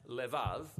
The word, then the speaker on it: lev: We're going to look at the fourth key word in this prayer, heart, which in Hebrew is sometimes pronounced levav, or more often in a shorter form, lev.